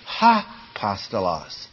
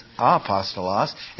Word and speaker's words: apostolos: So it would say, if it were turned around the other way, ha-postolos. As it is, it's apostolos, and if you don't pronounce it, it's just, it's one of those things that's just there.